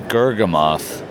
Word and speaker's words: Gurgamoth: Like, what's the process of getting a new Gurgamoth uh, build up there?